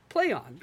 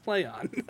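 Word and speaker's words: Plaion: They went from cock media that's supposed to be pronounced coke media to ply on that's supposed to be pronounced play on.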